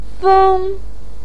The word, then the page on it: feng: 1